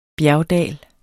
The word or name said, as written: bjergdal